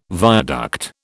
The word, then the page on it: viaduct: British English